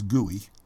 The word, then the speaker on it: GUI: Generally, the acronym is GUI, and we pronounce it as GUI.